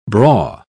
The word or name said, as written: braw